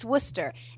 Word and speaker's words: Worcester: The English do that on purpose, though. It's Worcester. It's Worcester.